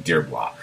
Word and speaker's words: Dearbhla: This person's name is spelled Dearbhla. So I'm going Dearbla.